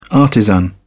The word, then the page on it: artisan: British English